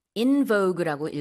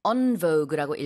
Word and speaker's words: en vogue: In vogue라고 읽어도 되고요, en vogue라고 읽어도 되는데 다시 유행하고 있어요.